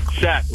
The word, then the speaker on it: Shaq: Sack, like Shaquille O'Neal.